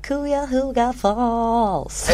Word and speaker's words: Cuyahoga Falls: Robert Bob Bardella föddes den 31 januari 1949 i Cuyahoga Falls. Braskar uttalet där. – Men jag gillar, det lät bra. Det lät som en Beach Boys låt. – Ja. Cuyahoga Falls.